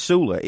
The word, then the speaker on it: Schuller: That's Schuller.